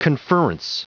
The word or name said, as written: conferrence